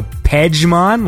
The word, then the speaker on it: Pejman: Because what's it? P-E-J-M-A-N. So Pejman, like that? – Yeah, I mean, it's totally spelt like Pejman, but it's pronounced Pejman. – Pejman.